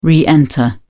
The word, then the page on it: re-enter: British English